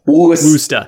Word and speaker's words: Worcester: Worcester. Worcester. – I pronounce it Worcester at first, and they were like, no, you're wrong. – It sounds like you're saying rooster, but you can't say your R's.